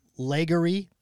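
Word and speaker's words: Legare: Okay, Légerie.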